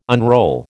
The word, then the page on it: unroll: American English